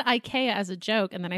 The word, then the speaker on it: IKEA: And I just wanted to let you know that the proper pronunciation of IKEA in Scandinavia is IKEA. The I sound is like an E sound here, so it's IKEA. At least that's how it's pronounced in Norwegian.